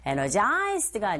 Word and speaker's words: energized: 발음했을 때 이 단어의 당세의 위치가 어디 있는지 정확히 아셔야 돼요. Energized가 아니고 첫 번째 강조해줘서 I feel energized 이렇게 발음하시면 됩니다.